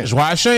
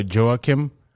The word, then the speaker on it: Joachim: Joachim is how you say it, like the French way. Like, sometimes I'll try to Americanize it for people. I would say Joachim. Yeah, which is like Joachim Noah, like the player that used to play for the Bulls.